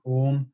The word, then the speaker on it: Om: with chanting using the actual sacred chant of Om, which is actually three syllables, ah, ooh, mm,